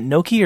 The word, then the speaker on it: gnocchi: Is it gnocchi or gnocchi? – Gnocchi.